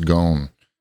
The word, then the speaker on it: Ghosn: It's called Wanted, The Escape of Carlos, I think it's pronounced Ghosn.